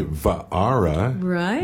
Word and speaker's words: Waara: Christine Wara. Or you could say Va'ara, which would be more of the Hawaiian, but it's spelled W-A-A-R-A.